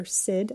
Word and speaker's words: Syd: My name is spelled with a Y. Like the city in Australia. So Wonder Sid, SYD.